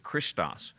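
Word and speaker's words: Christos (Χριστός): So Christ begins with CH to us, but it begins with an X in that language, and so we pronounce it Christos.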